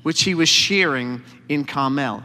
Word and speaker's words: Carmel: A certain man in Maon, who had property there in Carmel, that's how they taught me to pronounce it, my guide in Israel, says that Carmel, he was very wealthy.